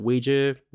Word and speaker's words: Ouija: So this one is about Ouija boards. Like, as I mentioned before, you can pronounce it Ouija, Ouija, but apparently the general rule of thumb is that it's a Ouija board.